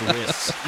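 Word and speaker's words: Witz: Born Chaim Weitz. – Oh, that's Gene Simmons. – Wow. – It's Wiss.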